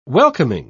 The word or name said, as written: welcoming